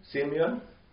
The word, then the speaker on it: Simeon: So, Simeon, how would you say that in English? – Simeon.